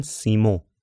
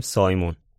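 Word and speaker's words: Simon: یه روز صبح سایمون، یعنی باباه، البته تو فرانسه بهش میگن سیمو یا سیمون، ولی ما همون میگیم سایمون.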